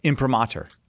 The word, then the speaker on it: Imprimatur: Imprimatur. – Yeah, I also pronounce it that way.